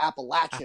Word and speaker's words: Appalachian: Maybe Nick Tamaro told me that Kurt Becker let him know it's not the Appalachian. It's the Appalachian. – Appalachian. – When you're in Kentucky, yeah.